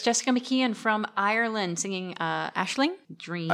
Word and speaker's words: Aisling: Aisling.